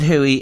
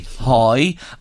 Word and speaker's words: Hoey: And just for people out there, he pronounces it Huey. If he was in Drogheda, we'd say Hoy. Other people would say Hoey, but it is the colloquial pronunciation from where you come. H-O-E-Y. Yeah. So Sean Tsunami, H-O-E-Y, Skill Shots on Facebook.